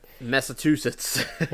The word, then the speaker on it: Massachusetts: Ma massa skjuter... Nej, jag kan inte uttala det. – Massachusetts. Ja, ja men liksom...